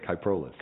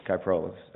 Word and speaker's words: Kyprolis: That's it, Kyprolis. – Kyprolis.